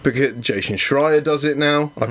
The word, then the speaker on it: Schreier: There's an S and a Sh, and I can't do both. – Yes, Schreier. – One or the other, so it's Jason Schreier.